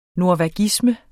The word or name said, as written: norvagisme